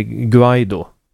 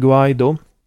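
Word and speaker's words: Guaidó: Det är lite osäkert på om man säger Guaido eller Guaido. Eller Guaido låter inte spanskt. – Jag har hört inspelningen nämligen av spansktalande människor som definitivt lägger betoningen på andra stavelsen.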